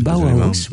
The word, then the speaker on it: Bauhaus: Le Bauhaus, je ne sais pas comment on le prononce, c'est Bauhaus, comme on dit. – Bauhaus, oui.